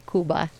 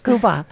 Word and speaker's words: Cuba: Is that how you say it, the real people? – I do say Cuba. – Cuba.